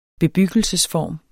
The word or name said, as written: bebyggelsesform